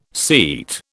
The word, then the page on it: seat: British English